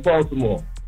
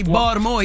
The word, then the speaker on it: Baltimore: You pronounced every word in. I mean, every letter in the word, yo. – Oh, y'all don't pronounce every word. – Yeah, what is it? – Baltimore. – Baltimore. – Like B-A-W.